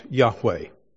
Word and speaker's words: Yahweh: Whenever we see that, that's a translation of the Hebrew name of God, which we pronounce Yahweh.